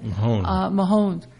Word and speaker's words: Mahomes: Mahomes.